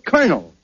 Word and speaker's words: Colonel: No, not Colano. – It is. – No, you see, that word is pronounced Colonel. – Colonel? – Yes, Colonel. Where did he fight? – Oh, my uncle. He fought with the original 13 Colonelies. – No, Costello, that's Colony. – Oh, Colony. You said it was Colonely. – No. – Colonely. Now, make up your mind. – Look, it's very simple. In military parlance, C-O-L is pronounced Cur, as Cur. – In Colonel.